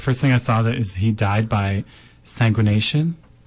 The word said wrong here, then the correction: exsanguination: Exsanguination. – How do you say it? – Exsanguination.